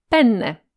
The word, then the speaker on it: penne: Żeby nie doprowadzić do niepotrzebnie krępującej sytuacji, składając zamówienie u włoskiego kelnera, warto starannie wymówić podwójne N. Włosi wymawiają je mniej więcej jako N przedłużone. Słowo penne z N pojedynczym oznacza po prostu penisa.